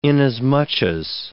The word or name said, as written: inasmuch as